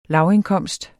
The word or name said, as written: lavindkomst